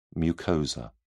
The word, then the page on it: mucosa: British English